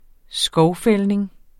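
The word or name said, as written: skovfældning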